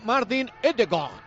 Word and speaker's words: Ødegaard: O cuando le coja Melchón en el Madrid, Martin Ødegaard. Es como se pronuncia en noruego.